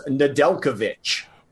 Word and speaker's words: Nedeljkovic: Yeah, that was one that I had to hear it a couple times because it is not pronounced the way it looks. But it is Nedeljkovic. – Well, there's an H on the end of the sound, but not on the end of the word.